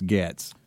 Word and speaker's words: Gaetz: There's plenty of places to go to understand how to say it right, and he kept saying we're saying it wrong. It's Gaetz.